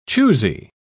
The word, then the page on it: choosey: British English